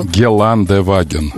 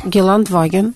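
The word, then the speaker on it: Geländewagen: Ну, ты сказала Геленваген. Это неправильно. – Так говорят. – Нет, не говори ты. Ты не говори. Ты на радио работаешь. Геландеваген. Геландеваген. Там Е читается или нет? Почему ты говоришь Геландваген? – А как? – Е читается. Ну, там написано Геландеваген.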